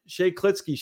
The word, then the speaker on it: Shea Klitzke: Let's bring in the head coach of the University of Concordia, Shay Klitsky.